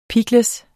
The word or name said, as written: pickles